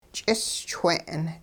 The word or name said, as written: ch'ischwe'n